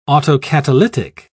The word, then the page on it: autocatalytic: British English